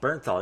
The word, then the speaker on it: Bernthal: Bernthal. Well, Bernthal, thank you.